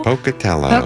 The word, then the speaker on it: Pocatello: And a listener from Idaho, I think this is pronounced Pocatello. – Pocatello, yeah.